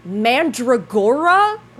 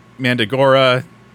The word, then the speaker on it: Mandragora: I have never heard you pronounce the word Mandragora before. – It's a tomato-tomato thing, right? It could be Mandragora, it can be Mandagora.